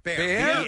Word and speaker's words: Bexar: B-A-R, yeah. – When you had that story in the B-File, I saw people texting the correct pronunciation is bear, but I had no idea what they were referencing. – Yeah. – Wow, okay. – I'm not familiar with that, but B-E-X-A-R is pronounced bear.